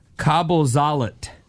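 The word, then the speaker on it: Kabelsalat: Okay, it says it's pronounced Kabuzalit. This is a German word. – Kabuzalit. – Kabuzalit. It's a German word that literally means cable salad.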